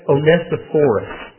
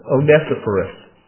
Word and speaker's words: Onesiphorus: It's Onesiphorus. Onesiphorus, which is different than Onesimus, which is in Philemon.